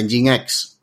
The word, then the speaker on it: Nginx: Eh, entonces, eh, bueno, de Nginx, que, que, es la pronunciación exacta.